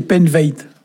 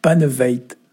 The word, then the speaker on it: paneved: Alors c'est Penveit. Penveit. Oui, alors il y a plusieurs prononciations, ça s'écrit P-A-N-E-V-E-D, et c'est très difficile à traduire.